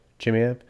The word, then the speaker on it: Chimaev: Chimaev. – Chimaev.